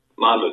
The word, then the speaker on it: Monmouth: So the, the number one best one would be Monmouth Park. I hope I'm pronouncing that right. – New Monmouth, Monmouth uh, Park. – Monmouth, yeah.